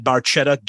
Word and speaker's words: Barchetta: To the Red Barchetta, Red Barchetta, Getty admits that he pronounced it wrong. It is a hard C sound.